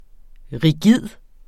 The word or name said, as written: rigid